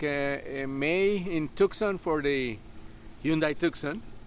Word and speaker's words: Tucson: The Hyundai Tucson event, yes. – Tucson, okay. – Yes.